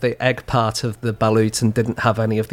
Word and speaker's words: balut: So this is balut. – Yep, so I had that balut. – Did you? Yep. Balut is how you say it.